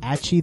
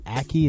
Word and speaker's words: Acci: Frank Hardy and Achi the Pal, or Aki, it's spelled A-C-C-I.